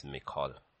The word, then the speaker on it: Michal: So I always pronounce it as Mikol.